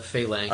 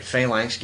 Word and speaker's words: Phalanx: It's published by, how do you pronounce it? – Phalanx. – Oh, that's right, Phalanx Games, and it was designed by Leo Colavini, which he's done tons of other games.